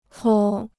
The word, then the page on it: 搓: cuō